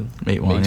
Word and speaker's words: Matewan: Matewan, yeah.